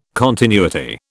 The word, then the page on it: continuity: British English